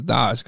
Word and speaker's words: Doge: Yeah, but it's Doge because it's D-O-G-E. – I know. That's why I said the E's silent. – Shh. – The E is silent.